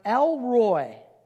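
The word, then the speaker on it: El Roi: And it's pronounced, here's how you say it. It's pronounced El Roy.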